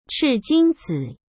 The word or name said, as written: chijingzi